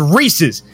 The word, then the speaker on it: Reese's: Why it's called Reese's, not Reese's. Reese is a name. There is an apostrophe at the end of the fucking name for the S. It is Reese's.